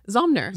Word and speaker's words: Zomnir: Oh my God, Zomnir? You said it right. Zomnir. – Zomnir, yeah. Good. Yeah. Good. I was nervous before I got... – I know. It's a tricky name because there's the M and the N together, yes.